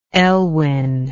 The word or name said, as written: elwin